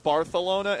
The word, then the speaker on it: Barcelona: It's pronounced Barcelona instead of Barcelona.